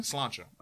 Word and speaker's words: Sláinte: Sláinte? – Sláinte.